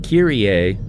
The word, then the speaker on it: Kyrie: In 1986, which group had a hit with the name Kiri? – If you mean Kyrie, that would be Mr. Mister. – Yeah, over here it's pronounced Kyrie.